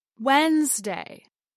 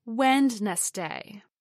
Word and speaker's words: Wednesday: And lastly, we have Wednesday, the third day of the work week, as Wednesday, not Wednesday.